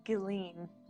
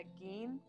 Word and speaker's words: Guillen: Today, I will tell you all about what happened to Vanessa Guillen. I always called it Guillen, but it's Guillen, which I did Google that, by the way, y'all.